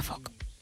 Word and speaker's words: folk: O sea, su regla clave es que la letra L es muda. – Anda. – Con lo cual se pronuncia folk. Dicho queda.